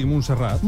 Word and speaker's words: Montserrat: Sí, Montserrat.